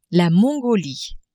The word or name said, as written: Mongolie